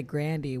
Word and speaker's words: Grande: And Ariana Grande, she pronounces it Grandy, which is also very interesting because that just sounds wrong to me, but that's how she says it.